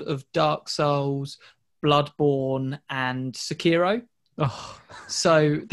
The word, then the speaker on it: Sekiro: I've wanted to play uh, Sekiro. Um, I probably said that wrong, but no, no uh, that's fine. Oh, is it right? Ah.